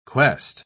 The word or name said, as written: quest